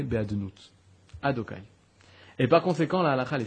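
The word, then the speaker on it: Adonai: Parce que Yud Kevavke, quand ces quatre lettres sont ensemble, ça ne se prononce pas. Ça se dit Adokai.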